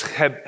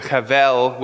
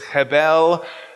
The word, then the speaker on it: hebel: It's chebel. You got a if in Hebrew that it's not just it's so it's chebel, or sometimes people pronounce it chevel with a V, with a more German kind of a way of pronouncing it, but it's chebel, chebel hebelim, all vanity of vanities.